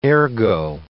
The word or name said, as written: ergo